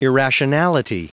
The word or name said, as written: irrationality